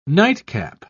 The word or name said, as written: nightcap